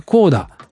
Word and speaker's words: CODA: Apple TV Plus 映画コーダ、ハリウッド批評家協会映画賞の 9 部門にノミネートという記事でして、 CODA と書いてコーダ。